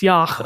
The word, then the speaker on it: yogh: It's spelled Y-O-G-H, so I thought it was yog or yaf, maybe, but it's yach, I think.